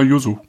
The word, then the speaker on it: Yuzu: Ja, Yuzu.